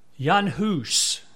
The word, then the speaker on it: Jan Hus: Jan Hus, as I said his name is pronounced, he lived after Wycliffe, whom we talked about the last two weeks, but he was living before Luther in the 14th century.